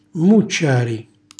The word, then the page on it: mmucciari: Calabrese